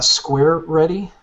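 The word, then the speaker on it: SquaReady: It's uh, Square Ready.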